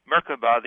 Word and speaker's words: Merkaba: Well, the pronunciation will vary from tradition, but it's basically pronounced Merkaba, the accent on the first syllable,